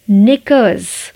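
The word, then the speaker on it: knickers: निकर्स के silent starting वाला के साइलेंट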